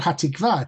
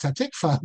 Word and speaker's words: Hatikvah: And as you know, in the we've spoken about this before, in Hatikvah, it's not Hatikvah, it's Hatikvah. That's actually Ashkenazic as well, the pronunciation.